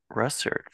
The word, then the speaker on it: Russert: Delighted on this episode to be talking with comics creator Phillip Russert.